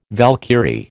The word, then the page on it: Valkyrie: American English